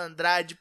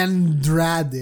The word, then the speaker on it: Andrade: Fez ali a correção, o jeito certo de falar Andrade para um americano. Não é Andrade, é Andrade.